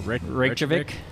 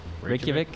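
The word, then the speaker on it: Reykjavík: Reykjavik. Reykjavik.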